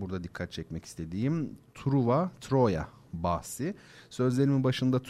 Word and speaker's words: Troya: Çünkü Truva kelimenin e, Fransızca telaffuzu. Kentin asıl ismi Troya. E, üstelik Türkçe telaffuza da çok uygun. Dolayısıyla Fransızca telaffuzu tercih etmek gibi bir e, gereklilik yok açıkçası. O bakımdan Truva yerine Troya demek e, gerekir.